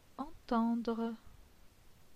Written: Entendre

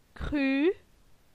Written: Cru

